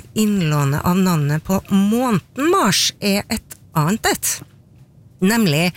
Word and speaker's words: Mars: Og i latin så ble navnet til krigsguden Mars uttalt nettopp med lang A. Mars.